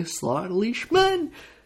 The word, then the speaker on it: Schliesman: I want to thank my guests, Charlie Vincent and Jeremy Schliesman.